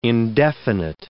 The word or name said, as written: indefinite